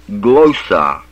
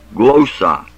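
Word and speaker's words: glossa (γλῶσσα): Now the word is glossa, long O, glossa.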